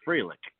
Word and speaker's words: Freilich: And by the way, it's pronounced Freilich. And my son tells me that's German for Merry, like Merry Christmas.